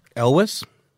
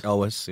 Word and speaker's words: Elwes: Elijah Hooper says, minor name pronunciation correction. For some reason, Carrie Elwes keeps getting mentioned, and you say Carrie Elwes. How do you pronounce it? – Elwes. – You say Elwes? – Elwes, yeah.